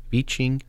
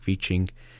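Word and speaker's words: Wiking: That's The Little Book of Hygge by Mike Viching. It's W-I-K-I-N-G. Even someone helped you with pronouncing, and I've said it wrong three times. But anyway, The Little Book of Hygge by Mike Viching, Danish Secrets to Happy Living.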